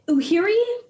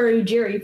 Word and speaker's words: Ujiri: Uhiri for Ujiri for sure.